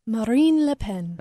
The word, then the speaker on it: Marine Le Pen: Marine Le Pen. – Okay. She said, you have to say it the French way.